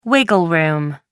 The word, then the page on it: wiggle room: American English